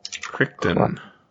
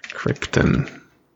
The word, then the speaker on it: Crichton: Scratch One by Michael Crichton. – Crichton. – I learned to spell his name. It was easier to pronounce it like it looks. Crichton.